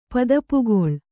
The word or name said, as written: ಪದಪುಗೊಳ್